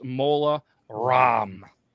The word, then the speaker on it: Mola Ram: Yep, this round is Voldemort versus Mola Ram. Like CD-ROM, but apparently it's spelled Ram.